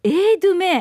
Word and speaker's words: e-dume: えっとね e-dume って書いて読み方は A ドゥメエードゥメ